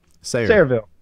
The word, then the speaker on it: Sayreville: I'm not sure how to pronounce their name. The Sayreville? – Sayreville.